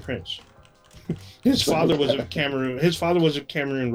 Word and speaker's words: Cameroon: So Yefit Kyoto, his father was, I believe it's pronounced Cameroon. Is that correct? Or yeah, that's right. – Cameron, but it's Cameroon.